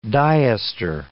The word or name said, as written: diester